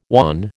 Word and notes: wan: American English

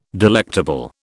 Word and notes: delectable: British English

